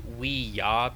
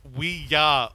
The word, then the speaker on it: Ouija: And It's actually Ouija because it's yes. But anyway. – Ah, I see. – Yeah. – So the movie is pronounced Ouija, Origin of Evil.